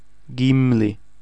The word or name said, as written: Gimli